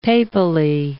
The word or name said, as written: papally